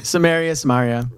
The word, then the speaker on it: Samaria: Jerusalem, Judea, Samaria, am I saying it right in English? Yeah. – Samaria.